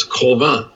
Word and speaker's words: Cauvin: Well, actually, his name, his given name was Colvin. C-A-U-V-I-N. Colvin.